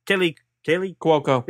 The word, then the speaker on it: Kaley Cuoco: Animated show starring Kaley Cuoco.